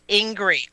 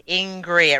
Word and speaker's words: Ingrid: It's Ingrid. Dennis, it's Ingrid. I-N-G-R-I. Ingrid. It rhymes with Ingrid. – Angry, but I'm not.